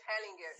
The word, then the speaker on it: Hellinger: Como que pronuncia o nome dele? Bert, é Bert? É Bert Hellinger. – Hellinger. É, Hellinger. É, tava Hellinger. falando errado.